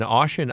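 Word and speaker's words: Ossian: And now, you were born in Oshin, Iowa, if I'm getting the name pronunciation correct? – Yes, that is correct.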